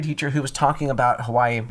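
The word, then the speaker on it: Hawaii (Hawaiʻi): I'm from Hawaii. – There's an apostrophe. There's a wait. That's how you pronounce it, my guy.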